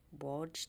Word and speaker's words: Wojtek: And it's, I can't pronounce its name, Wojtek.